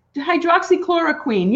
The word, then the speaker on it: hydroxychloroquine: Queen. Hydroxychloroquine. Yeah, that's how Tony tells me it's you're pronouncing it correctly from what I understand.